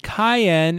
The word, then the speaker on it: Cyan: And Kayan is the correct way to pronounce Cyan. Cyan being the samurai character from the nation of Doma from Final Fantasy VI.